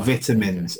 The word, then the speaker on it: vitamins: Or vitamins, how that is actually pronounced.